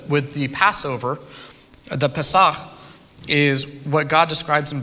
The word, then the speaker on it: Pesach: I mentioned the, the word Pesach. Correct, correct my pronunciation, Sarah. Pesach. yeah. Okay, thank you.